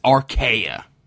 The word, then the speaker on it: Archaea: So I this was one of them that I you know read closely because I'm like I've never Arcania is it? – It's Archaea.